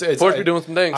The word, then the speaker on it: Porsche: I'll interchange it sometimes, but it's Porsche.